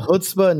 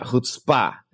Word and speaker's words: chutzpah: She's got the chutzpah, not the chutzpah. – The chutzpah. – Yeah, she's got the chutzpah, not chutzpah.